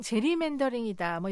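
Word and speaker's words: gerrymandering: 미국인들은 보통 제리맨더링이다 뭐 이렇게 발음하는데요.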